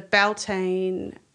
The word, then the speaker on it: Beltane: the Beltane, did I say that right? – You did.